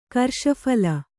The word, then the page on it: ಕರ್ಷಫಲ: karṣaphala